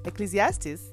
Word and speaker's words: Ecclesiastes: So Ecclesiastes it is. Ecclesiastes, yes, that's how it's pronounced.